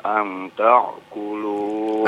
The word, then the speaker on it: ta'kulu: Wala 'alal maridhi kharaju wala 'ala anfusikum an ta'kulu. – Anta Kulu pakai hamzah ya, bukan pakai ain ya. – Wala ala anfusikum an ta'kulu min buyutikum au buyuti abaikum.